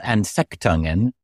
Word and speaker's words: Anfechtungen: So I believe the singular is Anfechtungen. – Oh, you're doing very well with that.